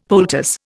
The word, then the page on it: poultice: Indian